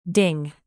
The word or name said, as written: ding